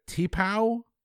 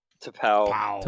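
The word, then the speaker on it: T'Pau: Is that T-Pow? T-Paw? – Tapau. – Tapau. Okay. I gave it two different pronunciations. Wasn't right either time.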